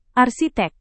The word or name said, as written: arsitek